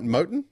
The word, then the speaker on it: Moten: Am I saying it right? Moten. – Yes, I had a laugh when you said Moten just a second ago.